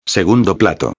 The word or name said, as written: segundo plato